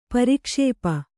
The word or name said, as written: ಪ್ರಕ್ಷೇಪ